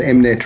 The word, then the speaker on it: Mnet: How do I pronounce that? Mignette VUCA? – The VUCA Awards, the Mnet VUCA Awards.